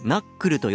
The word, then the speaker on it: なこそ: なこそと書いてナックルと読むそうですね